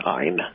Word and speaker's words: Ayn: I keep calling her Anne, but it's not really Anne, is it? – Well, sometimes pronounced Ayn. – Ayn. – Ayn. – Ayn. – Like Heil.